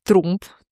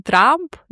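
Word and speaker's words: Trump: Прикол в том, что, короче, контекст того времени в США, когда Фридрих Трамп приехал в США, Фридрих Трумп, точнее, так надо его называть. – Да. – Потому что Трамп, да, А, это будет английское, да, уже произношение этой фамилии, а на немецком это будет Трумп.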